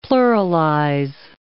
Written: pluralize

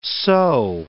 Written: sew

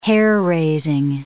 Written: hair-raising

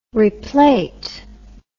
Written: replate

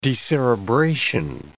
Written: decerebration

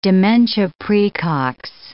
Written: dementia praecox

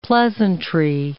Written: pleasantry

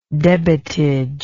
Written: debitage

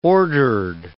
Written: ordered

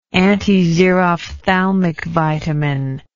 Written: antixerophthalmic vitamin

